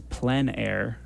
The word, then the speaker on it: plein air: I guess to kick things off, we wanted to talk about it's plein air, correct? correct? – Yes. Excellent pronunciation.